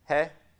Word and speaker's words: He: He, the H-E? Hey.